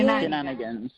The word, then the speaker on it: shenanigans: Bueno, invitamos a todos nuestros oyentes a que estén atentos de Nocómbaro, de Jenningans, ¿pronuncié? – Shenanigans. – Ah, perdona. – Es una perdona. – Palabra de Irlanda céltica.